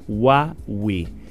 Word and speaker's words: Huawei: Huawei is pronounced Huawei, like Wahway.